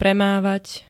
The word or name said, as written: premávať